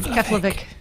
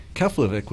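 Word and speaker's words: Keflavík: Keflavik. – Keflavik, which is near Reykjavik.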